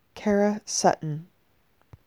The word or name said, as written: Karah Sutton